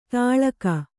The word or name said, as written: ತಾಳಕ